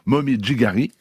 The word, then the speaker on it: Momijigari: Maulévrier respecte aussi une coutume ancestrale appelé au Japon Momijigari, et je le prononce vraiment à la japonaise, qui encourage à se promener pour apprécier simplement la beauté des arbres en automne.